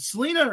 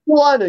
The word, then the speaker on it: Salina: I hope I said, is it Selena or Salina? – Salina, you said it right.